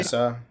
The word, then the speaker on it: Masai Ujiri: And I think that Masai Ujiri, is that how you pronounce his name? – Masai.